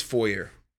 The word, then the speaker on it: foyer: Stepping into someone's foyer. – No, there's no hard R in there. – Foyer? – Yeah. – But it's spelled with an R.